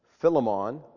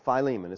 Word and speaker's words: Philemon: Philemon or Philemon. It's pronounced both ways.